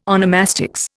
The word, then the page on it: onomastics: Indian